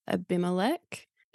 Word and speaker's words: Abimelech: The other thing I noticed when reading this was the contrast between the two, Abraham and Abimelech.